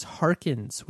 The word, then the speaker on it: Harkins: And for those of you, my last name is Harkins with an S, H-A-R-K-I-N-S. I've been noticing a lot of emails, you know, Ray Harkin. And it's, I don't know, maybe I'm just getting more aware of when people mispronounce my name or misspell my name. Anyways, Harkins, not like it's a big deal, but there it is.